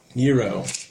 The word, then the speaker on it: gyro: Gyro. – It's spelled G-Y-R-O, but it's gyro.